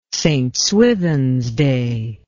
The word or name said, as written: St Swithins Day